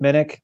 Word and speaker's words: Minnich: I can be found on Twitter at Chip Minnick. Last name is spelled M-I-N-N-I-C-H.